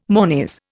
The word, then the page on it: monies: Indian